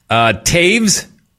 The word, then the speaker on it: Toews: Taves, T-O-E-W-S. Does not have a V in it, but you will pronounce it anyways.